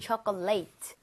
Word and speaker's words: chocolate: Chocolate 할 때는 앞에 O의 강세가 가죠. 그러면서 입 모양이 좀 벌려지겠죠. 그래서 초콜릿보다는 chocolate에 가깝고요.